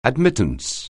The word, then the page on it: admittance: anglais